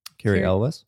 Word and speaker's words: Cary Elwes: Cary Elwes.